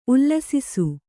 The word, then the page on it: ಉಲ್ಲಸಿಸು: ullasisu; Kannada